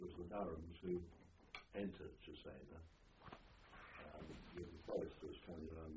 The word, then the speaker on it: Cesena: Cesena.